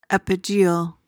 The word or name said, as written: epigeal